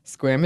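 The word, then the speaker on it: Sequim: A quick correction, Squim is pronounced Squim. It rhymes with swim, rather than either of the attempts Annie made in the episode.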